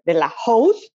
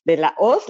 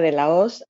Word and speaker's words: de la Hoz: First, my name is Marianela de la Hoz. Everybody pronounces it here de la Hoz, but no, it's de la Hoz, like the Wizard of Oz. The H is mute.